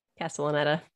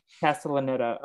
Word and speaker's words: Castellaneta: and then they they didn't like how it sounded so then uh, dan costa castaneda is that how you say his name castellaneta castellaneta uh,